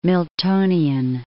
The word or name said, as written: Miltonian